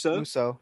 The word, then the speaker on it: Musou: You liked I don't know how you pronounce this, Musou? – Musou, yeah.